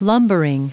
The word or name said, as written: lumbering